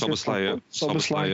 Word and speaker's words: Szoboszlai: And midfield, Szoboszlai, if that's how you pronounce Szoboszlai, yeah.